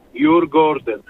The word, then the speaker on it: Djurgården: Jur, to jest zwierzę, Gordon Ogród, i ten klub w skrócie nazywa się Ogród Zoologiczny. Djurgården.